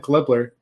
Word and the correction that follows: Gibler: Gibler, I can't get his name right, apparently.